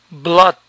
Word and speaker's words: blood: Sangre, blood, blood, la doble O suena aquí a, blood, flood. Firmar, sign, sign, final, ein, sign, design, benign. Ganar, earn, earn, E-A-R suena er, earn, learn, earth, early.